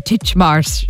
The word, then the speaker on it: Titchmarsh: Titchmarsh.